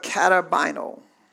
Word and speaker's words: katabaino: Comes down is the Greek word or words, it's a compound word of katabino. Katabino, and it's spelled K-A-T-A-B-A-I-N-O.